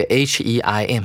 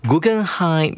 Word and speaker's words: Guggenheim: 而今天的标题有点难念哦，大家听一下，Guggenheim，然后注意到这个 H E I M，它是念成 Heim，它是德文了，所以这个博物馆呢，显然是一个德国人他所创办的。标题是 Guggenheim Museums。